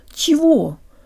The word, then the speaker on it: чего: guys, and today you'll learn how to pronounce the word чего or what. In Russian, че is always soft, pronounced with a tongue that is high and forward, not ch with a low tongue, but ch, ч.